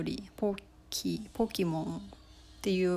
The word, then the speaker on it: poke: そのポケはハワイ語寄りの発音ちょっとローマ字みたいな感じなんですけどポッキーの方はアメリカ英語から来ているみたいです